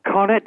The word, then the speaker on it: Conet: Yeah, Conet rhymes with bonnet.